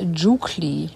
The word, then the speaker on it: Jukli: Das Eselfohlen heißt Jukli. Das spricht sich mit einem weichen J aus. Das J wird wie ein J gesprochen.